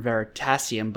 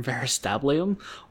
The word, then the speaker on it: Veritasium: I just made a joke from another podcast where they intentionally uh-huh. mispronounce Veritasium, the YouTube channel. They call him not Derek from Veritasium, but Dirk from Veristablium or something.